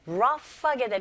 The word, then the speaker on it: rough: 네, rough하게 되면 여러분 gh로 끝나는 단어지만 소리는 rough. 이 gh가 f 발음이 납니다.